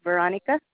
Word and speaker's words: Veronica: R-A-Q-U-E-L. Last name is Veronica. V-E-R-O-N-I-C-A.